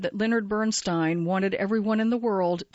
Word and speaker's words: Bernstein: And it's actually pronounced Bernstein with a long I. My apologies for the previous mispronunciations.